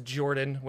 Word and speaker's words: Jorphdan: My name is Jorphdan with a silent PH in the middle, and I am joined always by my wonderful co host, Sir Lucien, over at Sir Lucien Gaming.